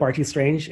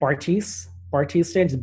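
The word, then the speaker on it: Bartees Strange: If you're not familiar with Bartiz Strange, and I think I'm pronouncing that correct, Bartiz Strange, B-A-R-T-E-E-S, Bartiz Strange.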